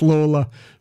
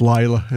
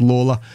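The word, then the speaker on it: Lalor: it's L-A-L-O-R, which Irish people pronounce Lawler, but I think it gets pronounced Layla in Australia. I think there's an electorate named after him, but we will stick with Lawler.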